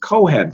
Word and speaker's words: Kohen: Kohen, pronounce it with me, please.